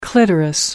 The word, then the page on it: clitoris: American English